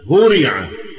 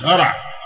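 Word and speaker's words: هُرِعَ: أشهرها طبعا فعل مشهور جدا هورع هورع مفيش هرع هورع يهرعون يو مش يهرعون يهرعون فعل هورع ده فعل مبني المجهول.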